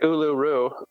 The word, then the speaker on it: Uluru: Urulu. That's funny because all the you know, it's like Uluru, but like in Japanese, it's Ururu, you know?